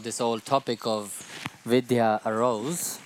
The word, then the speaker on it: Vidya: She writes V-I-D-Y-A. – Ona to píše V-I-D-Y-A. – But we pronounce it V-I-D-H-Y-A. Ale my to, Vidya, pro ve skutečnosti vyslané s tím dh, dha, vidya.